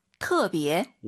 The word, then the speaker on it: 特别: 근데 발음하실 때터 아니고요. 트어라고 보시면 됩니다. – 特别.